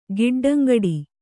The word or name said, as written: ಗಿಡ್ಡಂಗಡಿ